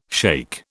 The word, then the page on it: shaikh: British English